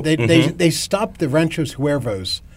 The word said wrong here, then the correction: Huevos: and forgive my Spanish pronunciation. – I think it's Huevos Ranchos, Was I even close?